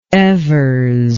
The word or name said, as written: evers